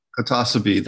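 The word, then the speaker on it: Clitocybe: There was a species of Clitacobe, or I might be mispronouncing it, Clitacibe, whoever it is that they pronounce that. Psilocybe. – Clitacibe. – Thank you, Clitacibe.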